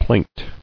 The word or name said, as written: Plaint